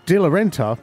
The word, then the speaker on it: de Renta: You also know that in 2002, De Renta, I think I said that right, yeah.